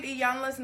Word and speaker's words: Iyanla: Most people get Iyanla's name wrong. So I always say, just say Iya, like it's an I. Yeah. So it's Iyanla Van Zandt.